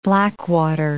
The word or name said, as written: blackwater